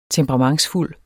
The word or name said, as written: temperamentsfuld